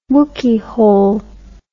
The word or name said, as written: wookyhole